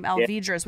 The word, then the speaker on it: Alvidrez: Alvidrez with a D.